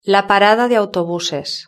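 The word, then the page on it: la parada de autobuses: European Spanish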